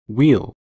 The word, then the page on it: weel: American English